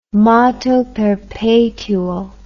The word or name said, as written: moto perpetuo